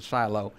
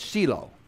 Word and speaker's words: Shiloh: You and I would pronounce it Shiloh. If you go to Israel, they'll call it Shiloh.